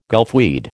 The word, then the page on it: gulfweed: American English